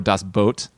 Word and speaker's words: Das Boot: Yeah, or Das Boot in German is how you say it.